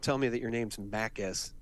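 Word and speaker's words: Makis: Dr. William Macus, or Macus. Mm-hmm. And yeah, it is Macus. I pronounced that wrong.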